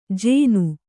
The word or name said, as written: ಜೇನು